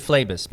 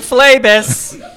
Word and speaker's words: Phlebas: Consider, is it Phlebas? – I pronounce it Phlebas, but Phlebas. – I don't know.